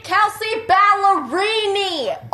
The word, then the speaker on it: Kelsey Ballerini: Kelsey Ballerini! Oh, my God. You can pronounce Fettuccine Alfredo, but you purposely get this girl's name wrong? – So that Chelsea...